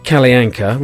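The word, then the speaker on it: Kalle Anka: He's put Kalayanka, pronounced Kalayanka, which is how I pronounced it, because I know how to pronounce verbs.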